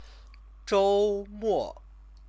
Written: zhoumo

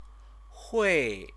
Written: hui

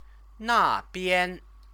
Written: nabian

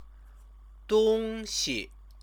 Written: dongxi

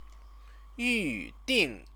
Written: yuding

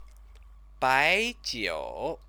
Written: baijiu

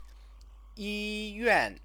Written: yiyuan

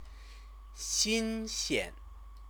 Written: xinxian

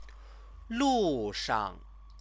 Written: lushang

